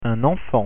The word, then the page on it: enfant: French